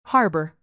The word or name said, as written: harbor